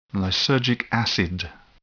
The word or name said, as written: lysergic acid